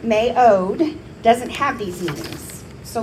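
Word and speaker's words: me'od (מְאֹד): So looking at the Hebrew word strength, it's actually pronounced me'od, and it's the least common Hebrew word we would use for the word strength.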